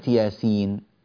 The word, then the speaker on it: يس: كلمة ياسين كما تلاحظون مكتوبة بحرفين ياء وسين، لكن هذه الكلمة في النطق تنطق خمسة أحرف: ياء مفتوحة، ألف مدية مد طبيعي بمقدار حركتين، ياء سين مكسورة، ياء مدية ست حركات مد لازم حرفي، وعندنا بعد ذلك نون ساكنة سين.